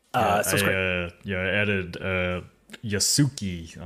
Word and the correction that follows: Yasuke: on Netflix to my watch list. – That is pronounced Yasuke.